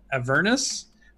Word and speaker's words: Avernus: Oh, and on the news channel, they talked about how you pronounce Avernus as opposed to Avernus. Ooh. – And yeah, yeah, yeah. – I've so been doing it wrong. – and Jeremy was saying the way he tries to remember is he tries to remember a furnace, so Avernus,